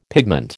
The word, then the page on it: pigment: American English